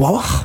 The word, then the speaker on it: hvala: Ja, og ja, det H-V-A-L-A det. Mm. Og det uttales Vava.